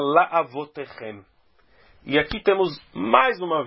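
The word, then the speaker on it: La'avotechem (לַאֲבֹתֵיכֶם): Então, o tá mesmo está embaixo do raf, e essa palavra, então, é uma oxítona que se lê La'avotechem. La'avotechem, chem, chem é a sílaba tônica.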